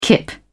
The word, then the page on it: kip: British English